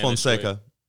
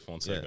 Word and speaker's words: Fonseca: We had a conversation the other day where, by the way, Derek, how do you pronounce the last name? Because there's like the Spanish. – Fonseca. – Same shit. – Yeah, so Portuguese and Spanish would be the same way.